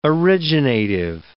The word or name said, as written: originative